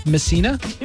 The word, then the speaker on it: Messina: This is the Show and Dina in Messina, New York. – How's it going? – Is that how you pronounce it? Messina? – Yeah.